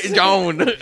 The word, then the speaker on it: jawn: And the John is spelled J-A-W-N. – John.